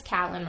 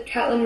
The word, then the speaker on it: Caitlin: Oh, well, this time we read How to Build a Girl by, I thought it was Caitlin Moran, but apparently it's Catlin Moran. – Yeah, that's right. I watched a few YouTube videos today. – You did? – Yeah, just to make sure I knew how to say it. – Oh, what did you learn? – Yeah, the Catelyn Moran is right.